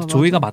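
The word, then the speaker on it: Zooey: 맞아요, 조이가 맞다고 발음상으로는 조이가 맞다고 그러는데 네네. 이미 한 10년 동안 주이, 거의 조이디 샤넬이라고 불렀는데 뭐 그런 것도 있고.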